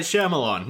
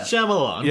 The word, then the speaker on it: Shyamalan: Like, M. Night Shyamalan, not that hard. Three syllables. – Yeah. – Shyamalan.